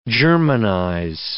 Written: germanize

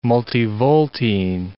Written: multivoltine

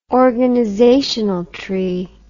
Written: organizational tree

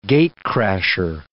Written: gate-crasher

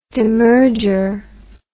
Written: demerger

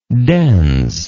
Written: dens

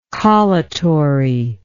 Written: collutory

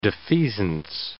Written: defeasance

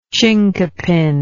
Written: chincapin

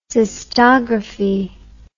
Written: cystography

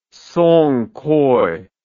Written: song coi

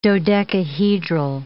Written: dodecahedral

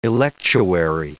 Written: electuary

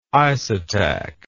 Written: isotac

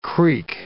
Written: creek

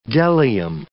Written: bdellium

